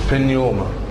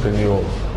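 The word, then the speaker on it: بنيوما (πνεῦμα): اشهر كلمة فيها النطق ده اللي هي كلمة بنيوما يعني روح. – بنيوما. – ايوه بنيوما. انطقها كده.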